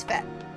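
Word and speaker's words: fête: Is it French? – It is French. – It is Fet.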